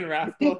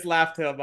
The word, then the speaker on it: Laftel: commented on so many Morge videos when he kept saying Raftel. – I'm like, Every time I would comment and say it's Laftel, by the way.